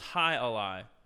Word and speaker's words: Jai alai: Oh, it's pronounced Hai Alai.